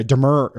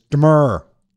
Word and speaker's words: demurrer: I had to have Google say that so I could pronounce that correctly, because I was originally calling it a demurrer or demurrer. It's demurrer.